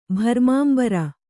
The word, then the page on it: ಭರ್ಮಾಂಬರ: Kannada